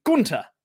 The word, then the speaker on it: Gunther: Therefore, here I am once again to remind everyone that Gunther does not have a TH in the name. The H is silent. Yes, it is technically Gunther, supposedly.